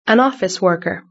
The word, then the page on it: an office worker: British English